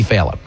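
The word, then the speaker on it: Failla: You know how many people have interviewed Jimmy Fallia, Falia, Fiella? It's Jimmy Fallia.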